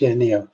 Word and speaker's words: Nioh: I'll just quickly point out, you're saying Noah, but you mean Neo, right? Yeah. – I don't know how it's pronounced. – It's just, N-I-O-H, Neo.